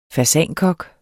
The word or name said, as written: fasankok